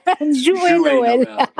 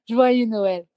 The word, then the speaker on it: Joyeux Noël: Joyeux Noël. – Noël. – Joyeux Noël.